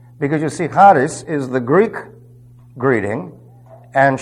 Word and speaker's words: charis: If you don't have it in your notes, it's charis. That's H-A-R-I-S. The H has a slight guttural sound to it. Don't ever pronounce it charis. Okay? That is not correct.